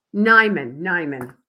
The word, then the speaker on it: Nimon: Did I pronounce that correctly? – Nimon. – Hard eye.